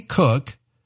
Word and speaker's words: Koch: Dr. Kathy Cook has recipes for better relationships with your adult kids. Her last name is spelled K-O-C-H. Pronounced cook, that's why I call it a cookbook.